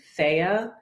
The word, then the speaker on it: Thea: It's T-H-E-A, so it looks like Thea, pronounced Thea, Thea Retreats.